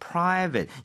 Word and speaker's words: private: 네, Private. – private. 이거 ate라는 스펠링은 8이 아니라 에 가깝다라고 한 적이 있었어요. 그래서 private이 아니라 다시 한번 들려주세요.